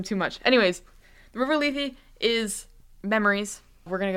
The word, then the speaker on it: Lethe: It's pronounced Lethe in Greek. But no one in English ever pronounces things the way that they're pronounced in Greek.